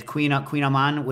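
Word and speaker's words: Kouign-amann: The Queen Aman, which is spelled KOUIGNAMANN.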